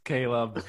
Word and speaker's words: Caleb: Caleb, you have one chance to say your name the right way. – Fine, it's Caleb.